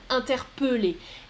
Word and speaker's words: interpeller: Mais le verbe infinitif interpeller, on prononce euh, interpeller.